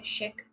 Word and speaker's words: Schick: my second one is this how you say it? Schick, like the Schick razors. – Yeah. – So this is it's like a multi-purpose facial razor.